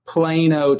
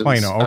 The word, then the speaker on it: Plano: So are you originally from, do you pronounce it Plano or Plano? – Plano, Texas. – Plano. Okay.